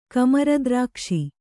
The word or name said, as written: ಕಮರದ್ರಾಕ್ಷಿ